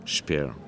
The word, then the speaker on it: Speer: Il s'appelle Albert Speer. Je ne sais pas si je prononce. – Speer.